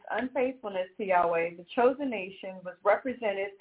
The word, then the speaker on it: Yahweh: And when it says Yahweh, I was talking about, we know it's pronounced properly in Hebrew, the Paleo-Hebrew, Yahweh.